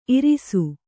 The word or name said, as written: ಇರಿಸು